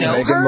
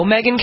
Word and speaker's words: Megyn: Born Megyn Marie Kelly.